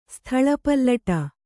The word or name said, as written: ಸ್ಥಲಪಲ್ಲಟ